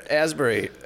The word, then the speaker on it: Asbury: You got to meet Heather Asbury. Asbury. – Yeah. – Raspberry. She said, say it like raspberry. – Yeah. But without the R.